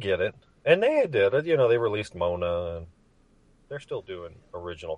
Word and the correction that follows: Moana: How is it pronounced? – It's Moana. – Oh, Moana.